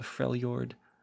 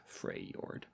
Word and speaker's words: Freljord: As we already established in weeks prior, or if you have forgotten, we will be beginning our series on the Freljord this week. That is spelled F-R-E-I-J-O-R-D, or sorry, F-R-E-L-J-O-R-D, Freljord, not Freyjord.